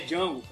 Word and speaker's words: Django: Meu nome é Django. O D é mudo.